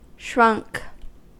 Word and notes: shrunk: American English

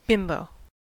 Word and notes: bimbo: American English

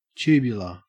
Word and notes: tubular: Australian English

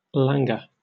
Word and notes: languor: British English

